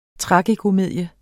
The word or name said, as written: tragikomedie